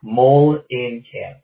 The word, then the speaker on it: Mullen Camp: It's like mole, like on your arm, in, and then camp. Mole in camp. Yeah, I know it's spelled weird.